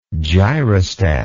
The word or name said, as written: gyrostat